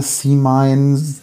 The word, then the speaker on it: C-Mine: Es ist C-Mine, C-Mine. Also Englisch ist eigentlich sinnvoll in Belgien.